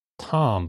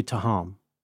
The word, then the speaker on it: Thom: I think he just says Tom, but it's spelled like Thom. – It wouldn't be Taham.